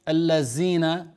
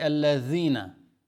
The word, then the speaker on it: الذين: Some people who did not acquire the knowledge about the rules of recitation, instead of saying صراط الذين الذين, the tongue, you need to put it a bit out between your teeth, so you don't say الذين, you say الذين.